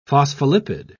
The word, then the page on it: phospholipid: British English